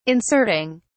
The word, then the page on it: inserting: British English